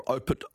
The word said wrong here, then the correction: Opuntia stricta: I knew I'd say that wrong. Opuntia stricta.